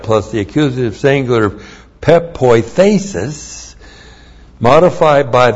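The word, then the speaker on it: pepoithesis (πεποίθησις): And then we have pepoithesis. Looks like that in the Greek. That's P-E-P-O-I-T-H, long E-S-I-S.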